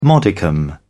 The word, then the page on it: modicum: British English